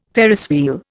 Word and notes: Ferris wheel: Indian

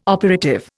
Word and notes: operative: Indian

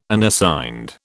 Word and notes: Unassigned: British English

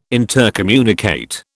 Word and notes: intercommunicate: British English

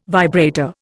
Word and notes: vibrator: Indian

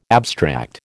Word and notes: abstract: American English

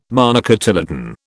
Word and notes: monocotyledon: British English

